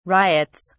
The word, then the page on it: riots: British English